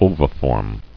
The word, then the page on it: Oviform: o·vi·form